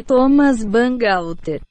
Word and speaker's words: Thomas Bangalter: Guy Manuel de Homem Cristo e Thomas Bangalter.